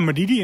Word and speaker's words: Madidi: Madidi, zeg ik dat goed? Ja, Madidi inderdaad, klopt.